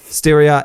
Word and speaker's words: Styria: And the Austrian public had a chance to have their say on that, where they had a state election in Styria. Am I saying that right? Close enough? – I think so. – Styria in late November.